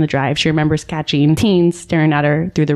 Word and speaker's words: Thuns: It's spelled kind of weird because I'm sure it's like a South African name. It's spelled T-H-U-N-S. But looking it up, his name's Teens.